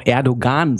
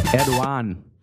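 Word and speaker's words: Erdoğan: Erdogan. – Das, im Duden steht, dass beide Aussprachen richtig sind.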